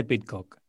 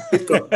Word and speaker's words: Pidcock: Bueno, el Giri dice Pidcock. – Pidcock, vale.